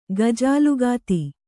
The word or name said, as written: ಗಜಾಲುಗಾತಿ